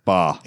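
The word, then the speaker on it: Bá: Apparently, we looked up, we looked up how to say Gabriel Ba, and it is Ba.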